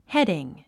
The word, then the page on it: heading: hédiŋ; ヘディング